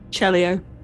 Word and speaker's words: Celio: Celio, is it pronounced? – Celio. Like Celio, cello, okay. But EO.